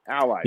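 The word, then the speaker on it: ALLi: Is it Ally or Ally? – How should I be pronouncing that? Ally. – Yep, that's Ally. Just rhymes with the standard word, Ally.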